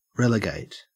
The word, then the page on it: relegate: Australian English